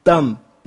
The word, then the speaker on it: dumb: 这 dumb 那 b 不发音，有两个意思。